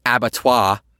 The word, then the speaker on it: abattoir: By the way, Maeve drops a French word here, abattoir, which is slaughterhouse, if you're wondering. I believe Maeve is saying Dolores is leading humans to an abattoir, or as the French correctly pronounce it, Abattoir.